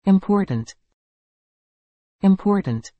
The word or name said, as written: important